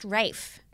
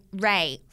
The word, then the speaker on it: Ralph: It's pronounced Rafe. – Rafe. Oh. – Yeah. Or Ray Fiennes. It's either pronounced Rafe or Ray. – I thought it was Ralph Fiennes. – It's spelt that way. – Oh. – But it's pronounced either Ray or Rafe.